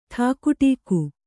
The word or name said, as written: ಠಾಕುಟೀಕು